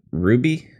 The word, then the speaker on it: RWBY: R-W-B-Y? – I guess that's how it's pronounced. I used to say the letters, and then when I worked at FYE, someone corrected me. So I think that's right.